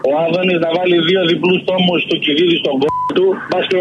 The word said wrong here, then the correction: Θουκυδίδη: Μα Θουκυδίδη, Θουκυδίδη.